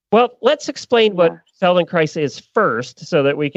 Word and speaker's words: Feldenkrais: So it's ironic that you're on today to talk a little bit about, is it Feldenkrais? Is that how you say it? – Is it? Yes, Feldenkrais. Yeah. – Okay. So with rice. Yeah. – Okay, good. That's a good way to remember it.